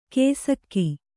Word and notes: ಕೇಸಕ್ಕಿ: kēsakki

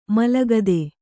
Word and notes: ಮಲಗದೆ: malagade

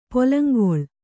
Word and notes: ಪೊಲಂಗೊಳ್: kannada

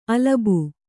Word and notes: ಅಲಬು: alabu

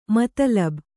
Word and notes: ಮತಲಬು: Kannada

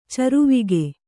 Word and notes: ಚಾರ್ವಾಕ: cārvāka

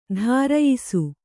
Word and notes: ಧಾರಯಿಸು: dhārayisu

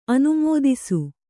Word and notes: ಅನುಮಿತಿಸು: anumitisu

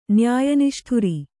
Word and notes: ನ್ಯಾಯನಿಷ್ಠುರಿ: nyāya niṣṭhuri

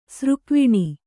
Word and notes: ಸ್ರಗ್ವಿಣಿ: sragviṇi

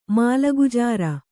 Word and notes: ಮಾಲಗುಜಾರಿ: mālagujāri

